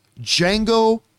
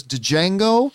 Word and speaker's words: Django: I referred to it as Django until the movie so properly reminded us. The D is silent many, many times.